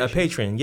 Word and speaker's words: Patreon: A Patreon, is that the correct pronunciation? – Yeah, a Patreon, yes.